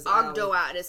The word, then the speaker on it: Ogdoad: Spell it for people, because Ogdoad. So it's O-G-D-O-A-D.